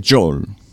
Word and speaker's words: Joel: Joel Grey y Joel, el piano man, que se pronuncia Joel, no Joel. Pero bueno, es un paréntesis que cierro. – Billy Joel. – Billy Joel, no es Joel, sino Billy Joel.